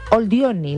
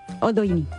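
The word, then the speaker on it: Oldoini: Virginia Oldioni, la condesa de... ¿Oldioni? ¿Lo he dicho bien? – Oldioni. – Ah, perdona. No, está bien que me corrijas.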